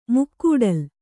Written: ಮುಕ್ಕೂಡಲ್

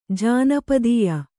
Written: ಜಾನಪದೀಯ

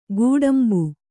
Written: ಗೋಡುಂಬ

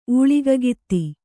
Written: ಊಳಿಗಗಿತ್ತಿ

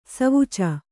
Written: ಸವುಚ

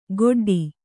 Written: ಗೊಡ್ಡಿ

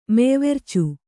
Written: ಮೆಯ್ವೆರ್ಚು

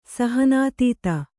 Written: ಸಹನಾತೀತ

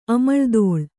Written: ಅಮಳ್ದೋಳ್

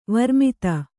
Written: ವರ್ಮಿತ

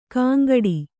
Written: ಕಾಂಗಡಿ

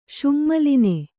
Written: ಶುಙ್ಮಲಿನೆ

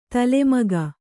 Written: ತಲೆಮಗ

